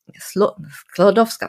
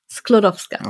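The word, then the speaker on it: Skłodowska: November 1867 geboren als Maria Salomea Sladowskowa. Sklodowska, Entschuldigung. Ich werde diesen Namen nicht mehr aussprechen, zum Glück. Mhm. Wie war die nochmal? Sklodowska.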